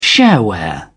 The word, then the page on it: shareware: British English